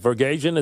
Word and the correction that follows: Vasgersian: is that how you say it? – No, you're not saying it right. – They don't know how to say it. – And by the way, Francesa, being a self-proclaimed baseball guy, should know very well about Matt Vasgersian.